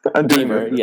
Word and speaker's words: Deaver: Deaver. Yes. – Oh my God. Why can't rhymes, I do this? – Rhymes with beaver and cleaver.